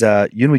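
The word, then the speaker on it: Yunwi: It is Yunwei Junsti. – Yeah, that. I cannot wrap my head around. Well, how is that spelled? – It's spelled, well, Yunwei is spelled, it's Y-U-N-W-I.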